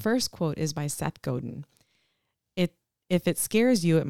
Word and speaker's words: Godin: So the first one is by, and I might say the last name wrong, it's Seth Godin.